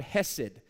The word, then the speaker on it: hesed: It's the Hebrew word hesed. We would spell it H-E-S-E-D.